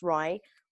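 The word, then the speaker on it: RIE: It's pronounced Rai. Rai is an acronym for Resources for Infant Educarers.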